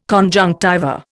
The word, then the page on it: conjunctiva: Indian